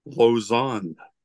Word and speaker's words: Lausanne: It's Lausanne, right? – Yeah, Lausanne. No, it's French. You know, French. – You got to do like this when you say. – Yeah, Lausanne. – Okay. Lausanne.